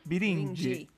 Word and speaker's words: Birinci: Só que a, a pronúncia é Birindi.